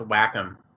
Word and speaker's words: Wacom: That I'm sorry, but that's pronounced Wacom.